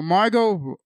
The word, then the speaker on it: Margot: Margot Robbie. Okay, I think that's Robbie. And Harley Quinn. – Right, the T is silent.